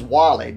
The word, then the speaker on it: Walley: My last name is Wally, W-A-L-L-E-Y.